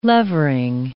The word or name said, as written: levering